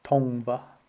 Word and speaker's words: Tongva: I looked up that pronunciation because I really did not want to mess that up. It is Tongva, Tongva.